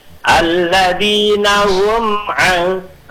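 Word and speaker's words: alladzina hum an (الَّذِينَ هُمْ): Alladinahum ang misalnya itu nggak boleh karena mim itu bukan sidah tapi sifatnya mim itu tawasud. Jadi bacanya alladzina hum an, tetap dibaca harus jelas mimnya, enggak boleh ada dengung, tetapi tidak boleh dihentakkan.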